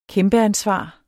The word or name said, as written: kæmpeansvar